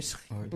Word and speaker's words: thriller: Attends, vous prononcez ça comment? – Ouais. – Thriller? Oui, thriller. – Ouais, tu l'as bien prononcé.